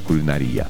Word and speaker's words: кулинария: Так что ваша женская логика привела к правильному варианту ответа. Кулинария. Вот так. Но и так, в принципе, не будет страшно ничего. Привет ваш. И попробуйте, кстати, слово «кулинария» сказать теперь в своем привете как-то с правильным ударением.